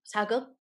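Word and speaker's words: sageuk: I tried to watch, I think it's how, is that how you pronounce it? – Saeguk? – Saeguk, okay.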